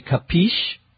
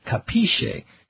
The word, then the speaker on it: capisce: It comes originally from the Italian, but Americans give it their own American pronunciation. We say capisce. The Italian pronunciation, I think, would be capisce, but we say capisce.